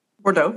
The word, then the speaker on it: Bordeaux: Apparently, in France, just outside of Bordeaux, did I say that right? – Bordeaux, yeah. Okay. Come on, it's a wine.